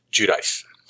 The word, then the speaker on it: Giudice: Which, to me, looking at that and hearing it is an awesome name because it's spelled G-I-U-D-I-C-E, but it's pronounced Giudice.